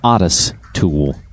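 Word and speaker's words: Otis Toole: John Walsh's Most Wanted, finally captured, and I was mispronouncing the name. It's Otis Tool.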